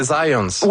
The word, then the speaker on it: Zajonc: c'est Zajonc. – Ouais? – OK. J'ai-tu pas dit Zajonc? – Ben, j'avais dit plus Zajonc, mais... – J'ai prononcé un petit peu à l'anglais. J'ai prononcé mon polonais à l'anglais.